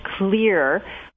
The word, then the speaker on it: Xlear: You could use a solution that has a colloidal silver or nano silver, or you can use a product made with xylitol called Clear, which is spelled X-L-E-A-R,